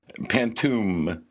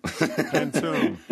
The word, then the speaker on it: Pantoum: Pantoum. – Pantoum. – Okay. – What is it exactly? – It's an old Malay form. So originally it's kind of like a folk poem. It's called Pantoum with a U.